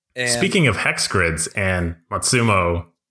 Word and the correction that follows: Matsuno: did I just say it right? – It's Matsuno, isn't it? – Matsuno games.